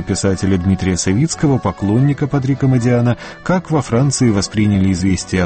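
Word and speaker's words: Модиано: Патрик Модиана, роман «Кафе утраченной молодости». Во Франции, разумеется, ставят ударение на последний слог, но Модиана – итальянская фамилия.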